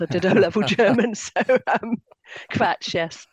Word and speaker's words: Kvatch: And you pronounced the K, right? Kvatch, is that right? – It's supposed to be the phonetic spelling of the German for rubbish.